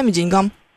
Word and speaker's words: деньгам: Во всех остальных случаях у нас будет ударение на «а». Вот так, деньгам.